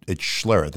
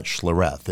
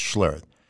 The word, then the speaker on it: Schlereth: And I go, well, it's Schlereth. It's not Schlereth. It's Schlereth.